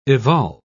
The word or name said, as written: evolve